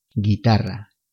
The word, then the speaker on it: guitarra: Guitarra. Guitarra se deletrea G U I T A R R A. La letra U no se pronuncia, recuerda.